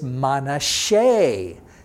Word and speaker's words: Manasseh: Well, Manasseh, as you would say in English, Be'ivrit in Hebrew is Manasseh. That S is a sh sound.